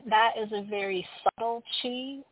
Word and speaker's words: qi: And then we're also working with this concept of chi, A lot of people see it as QI. It's the old Taoist way of how to spell it. That's how I spell it. It's pronounced chi. Some people see it the Japanese way of KI. It's pronounced ki.